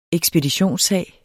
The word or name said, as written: ekspeditionssag